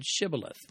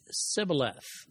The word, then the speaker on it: Shibboleth: And I quote, "The clever test faced by the disgraced soldiers was to pronounce the word Shibboleth, which means either an ear of grain or a flowing stream. The Ephraimites were identified when they managed to say only Sibboleth.